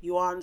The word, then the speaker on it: Xuan: So Yuan, it's spelled X-U-A-N, Yuan Sword Luminary.